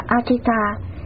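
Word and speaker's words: Ajita: Ngài A Dật Đa, tiếng Ấn Độ đọc là Achita, chữ Hán là Vô Năng Thắng, là tên riêng của Ngài Di Lặc Bồ Tát, tiếng Ấn Độ đọc là Matriya.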